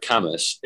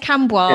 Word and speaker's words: Cambois: partly because nobody knows how to say it, because it's spelled Cambois, as in, like, it sounds French. – It does. I thought it was like framboise. So when I first moved, I was like, oh, yes, Cambois. Yes.